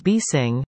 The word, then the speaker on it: Nipissing: Embassying Power, pronounced B-Sing, the in is silent, doesn't sell power.